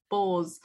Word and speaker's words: Bose: So it's a Bose, how do you say that? I never know how to say it. – Bose one.